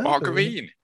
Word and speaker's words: margarine: As in margarine, but with a hard G. Oh. Margarine.